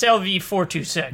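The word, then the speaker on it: LV-426: I said it wrong earlier too, but it's LV426.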